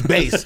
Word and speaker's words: BASS: Yeah, that's BASS Customs. And it's not Bass, it's Base. So that's BASS.